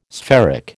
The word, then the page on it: spheric: American English